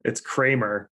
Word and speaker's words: Cremer: changed their last name to C-R-E-M-E-R, and then migrated to the United States through Canada. So it's Kramer.